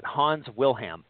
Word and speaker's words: Wilhelm: So there's a gentleman by the name of Hans Willem, Wilhelm. I'll just kind of phonetically pronounce that, Hans Wilhelm.